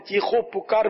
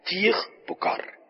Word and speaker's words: چیخ پکار: جیسے ہمارے یہاں چیخو پکار بولتے ہیں, چیخو پکار بھی اہل لغت کے نزدیک ہے کہ واو یہاں آئے گا نہیں, بلکہ چیخ پکار دونوں کے ایک ہی معنی ہے نا, چیخ پکار, یہ لفظ تلفظ درست ہوگا.